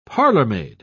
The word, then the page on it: parlourmaid: British English